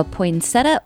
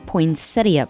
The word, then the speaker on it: poinsettia: Are you a poinsettia or a poinsettia person? Both pronunciations are correct, and today we celebrate National Poinsettia Day.